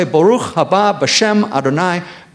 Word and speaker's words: Baruch haba b'shem Adonai: Verse 39, he says, "For I say to you, from now on you will not see me until you say," and in Hebrew we would pronounce it this way, "Baruch haba b'shem Adonai."